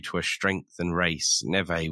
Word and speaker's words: Neve: I would say Nev or Neve. – I think they pronounce it Neve. – Neve. Yeah.